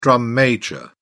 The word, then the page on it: drum major: British English